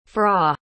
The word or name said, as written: fra